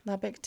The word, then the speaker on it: Lubbock: The boys and girls had qualified for a meet in Lubbock. Is that how you say it? – Lubbock? – Lubbock, Texas.